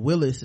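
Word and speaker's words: Wylis: At any rate, so Lyanna comes in on a horse and she's like, you know, why don't y'all let Wallace fight, or Willis is they pronounced it, and Willis is Hodor.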